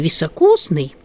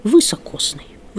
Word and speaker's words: високосный: И, как пишут наши словари, в просторечии, в народной речи можно услышать слово «высокосный». «Высокосный». Вот, ну, это я ударение сделала специально. Но я думаю, люди, которые с университетом связаны, не должны относить себя к просторечию, к народному, а должны говорить на литературном. «Високосный год».